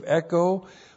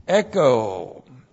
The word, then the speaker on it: echo: This is the present active indicative of echo, plus the accusative singular of martyria with a definite article, plus the prepositional phrase and plus the dative singular of autos. Echo. E-C-H long O. Echo.